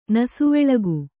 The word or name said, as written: ನಸುವೆಳಗು